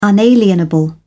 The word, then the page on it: unalienable: British English